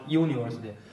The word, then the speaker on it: university: 你大学对啊，对啊，那个你读发的是 university，那个重音不是在前边啊，那个重音在后边，所以你下来之后要跟你的英语老师你再多读几遍，让他再给你修改修改。